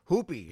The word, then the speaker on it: Hoopii: Then Sol Hoopi, his name is spelled H-O-O-P-I-I. You see, the second I is silent.